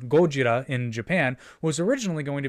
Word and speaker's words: Gojira: So, you take the Japanese pronunciation of gorilla, which would be Gorira, and... Gordita? And not Gordita. Gordita. And the Japanese word for whale, Kujira. So you have Gojira.